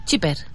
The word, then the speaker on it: Schipper: No, S-C-H-I-P-P-E-R. Shipper. Ya lo he dicho bien.